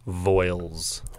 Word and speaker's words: Voiles: My last name is pronounced Voiles.